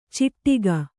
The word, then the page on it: ಚಿಟ್ಟಿಗ: Kannada; ciṭṭiga